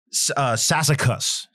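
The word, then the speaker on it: Sassacus: Sassicus. Sassicus, not Sassicum.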